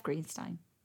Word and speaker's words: Greenstein: Fondly from Jeff Greenstein. Rhymes with free wine.